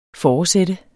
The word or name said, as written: foresat